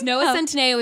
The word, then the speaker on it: Centineo: Is that how you say his name? – Yes, Noah Centineo is now Milo.